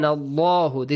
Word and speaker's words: الله: وَكَانَ اللَّهُ This is the example of the word Allah pronounced heavily.